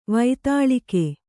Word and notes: ವಾತೂಲಿಕೆ: vātūlike

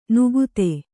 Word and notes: ನುಗುತೆ: nugute

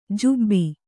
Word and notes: ಜುಬ್ಬಿ: jubbi

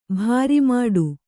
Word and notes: ಭಾರಿಮಾಡು: bhāri māḍu; Kannada

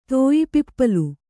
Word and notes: ತೋಯಿಪಿಪ್ಪಲು: Kannada

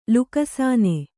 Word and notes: ಲುಕ್ಸಾನ: luksāna

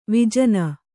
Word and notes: ವಿಜನ: vijana

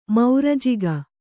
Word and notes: ಮೌರಜಿಗ: maurajiga

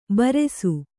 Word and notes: ಭೃಶ: bhřśa

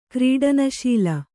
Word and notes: ಕ್ರೀಡನಶೀಲೆ: Kannada

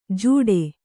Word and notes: ಜೂಡೆ: jūḍe

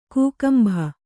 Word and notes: ಕಕ್ಕಂಬು: kakkambu

